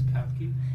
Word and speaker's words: Papke: Alex Papke. – Alex Papke. – How do you spell that? – P-A-P-K-E.